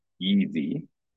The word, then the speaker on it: YSE: And so a collaboration that I'm closely involved in is called the Young Supernova Experiment, or YSE, pronounced Yeezy.